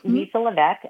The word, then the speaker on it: Levesque: My website isn't as interactive, so it's Lisa Levesque on LinkedIn. Message me. The last name is spelled L-E-V as in Victor, E-S-Q-U-E.